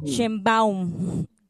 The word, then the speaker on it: Sheinbaum: Claudia Sheinbaum, ¿lo pronuncié bien, doctora? – Sheinbaum. – Sheinbaum.